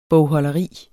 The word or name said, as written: bogholderi